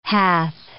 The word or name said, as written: hath